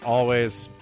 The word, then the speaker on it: Alvvays: Of course, it's Alvvays. I just said the double V in there because that's how they spell it.